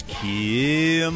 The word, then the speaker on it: Keel: And every time I did it yesterday during the commercial break, Sean kept going, no, it's Keel Mortgage. He says, you're doing it wrong. – Yeah, it's like pronounced with two syllables instead of one sort of incremental increase up.